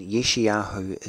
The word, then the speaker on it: Yeshayahu: Another thing that Yeshayahu talks about as well in chapter 65, verse 24, again, Yeshayahu is how we correctly pronounce the name Isaiah in Hebrew.